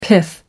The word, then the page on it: pith helmet: American English